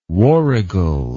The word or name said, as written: warrigal